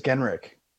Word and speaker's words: Genrich: And it suddenly occurred to me, Eric, Mr. Mayor, that I thought I was right all this time with Genrich, but some people say Genrich, and you get to set the record straight. – It is Genrich. – Genrich it is. – Yeah, okay. It's a good Pomeranian name. So it's, yeah, it's K sound at the end there.